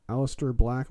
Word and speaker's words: Aleister Black: Aleister Black, am I saying that right? – Yeah.